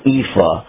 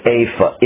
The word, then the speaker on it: ephah: So I'm going to kind of give you something so that you can understand what an ephah, or more accurately pronounced, an apha is.